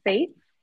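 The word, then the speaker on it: Fête: Oh, Simple Fate. Like it's Fate is the French word for like an extravagant event or like some sort of celebration. I assume that's where the word festival comes.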